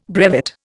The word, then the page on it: brevet: Indian